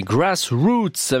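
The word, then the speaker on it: Grass Roots: Les grassroots, comme ça que ça se prononce, les grassroots, avec ce titre de 1971, Temptation Eyes sur RTS Première.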